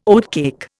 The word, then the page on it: oatcake: Indian